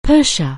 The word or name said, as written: Persia